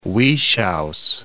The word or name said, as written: Wieschaus